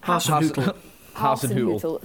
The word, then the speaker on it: Hasenhüttl: help me out here on the Southampton manager. – Hasenhüttl.